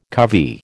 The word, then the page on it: covey: American English